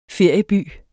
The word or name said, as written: ferieby